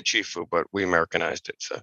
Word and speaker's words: Cifu: So as I said, CEO of Virtue Financial is with us today, Doug Cifu. Doug, I'm going to parenthetically pause. Am I pronouncing Cifu correctly or am I bungling? – You are doing a great job.